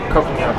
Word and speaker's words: Coconino: How do you pronounce Coconino Cowboys? – Coconino.